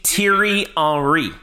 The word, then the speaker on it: Thierry Henry: Thierry Henry.